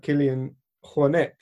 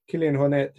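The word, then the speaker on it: Kilian Jornet: And for every person with a kind of intensive obsessive background, there's people like Kilian Hornet, is how I've since realized it's supposed to be pronounced. Kilian Hornet, who is quite easygoing with it all.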